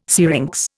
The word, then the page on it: syrinx: Indian